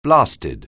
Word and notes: blasted: anglais

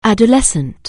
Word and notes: adolescent: anglais

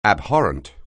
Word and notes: abhorrent: anglais

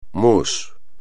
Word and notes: mousse: Spanish

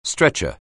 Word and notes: stretcher: anglais

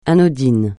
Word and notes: anodin: francais

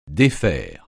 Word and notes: défaire: French

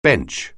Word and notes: bench: anglais

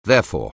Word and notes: therefore: anglais

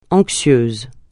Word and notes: anxieuse: francais

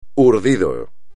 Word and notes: urdido: espagnol

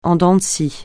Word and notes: en dents de scie: français

